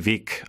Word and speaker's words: Weg: Was ich mir auch angewöhnt habe, ist so Endlaute wie bei Weg oder sowas, dass ich das eher mit Weg, also mit K am Ende ausspreche.